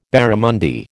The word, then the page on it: barramundi: American English